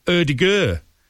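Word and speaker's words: Ødegaard: And you actually pronounce it Erdegur.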